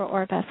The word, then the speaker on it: Orpah: So I have a lot of respect for Orpah for that reason.